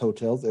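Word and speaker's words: Heaux Tales: Also, I believe that's pronounced Hotels. It's H E A U X.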